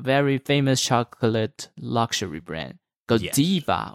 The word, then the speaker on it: Godiva: But, you know, obviously, if we want to stick to the Belgian roots, then Godiva it is.